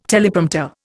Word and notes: Teleprompter: Indian

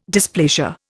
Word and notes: dysplasia: Indian

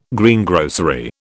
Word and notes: Greengrocery: British English

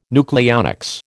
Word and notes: nucleonics: American English

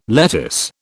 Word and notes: lettuce: British English

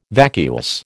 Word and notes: vacuous: American English

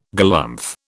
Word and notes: galumph: British English

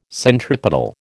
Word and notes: centripetal: American English

